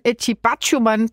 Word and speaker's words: Batshuayi: Så skal vi sidde på til Horsens Kelvin, og nu undskylder jeg på forhånd, Eti Batshuayi bragte AC Horsens foran i første halvleg.